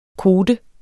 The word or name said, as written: kote